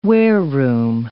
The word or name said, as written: wareroom